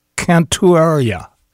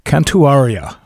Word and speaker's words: Cantuaria: Vinicius Cantuaria. Cantuaria, I believe is how it's pronounced. Close, anyway.